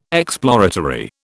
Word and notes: exploratory: British English

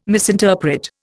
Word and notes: misinterpret: Indian

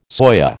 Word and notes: soya: American English

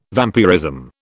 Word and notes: vampirism: British English

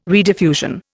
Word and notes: rediffusion: Indian

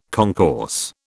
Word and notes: concourse: British English

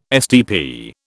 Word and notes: STP: British English

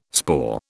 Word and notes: spore: British English

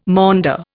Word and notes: maunder: Indian

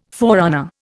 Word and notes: forerunner: Indian

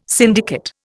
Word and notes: syndicate: Indian

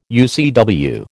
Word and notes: UCW: American English

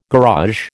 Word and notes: garage: American English

